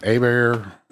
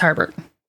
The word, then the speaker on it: Herbert: Yeah, that's in Louisiana, he would have been Abear, but because he's in Texas, he's Herbert.